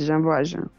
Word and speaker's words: Jean Valjean: Você tem o Jean Valjean. É, falei certo? – É exatamente, Jean Valjean.